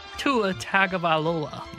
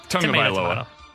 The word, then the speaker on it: Tagovailoa: Tua Tagovailoa. – You butchered his last name. – Eh, close enough. – Eh, Tagovailoa.